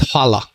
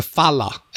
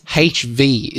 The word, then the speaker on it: hvala: And a lot of people struggle with that word because the word is spelled H-V-L-A, sorry, H-V-A-L-A, hvala. So, it's, when people, I explain it to them, they go hvala. And I'm like, it's not hvala, it's H-V is the pronunciation.